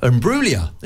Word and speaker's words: Imbruglia: Imbruglia.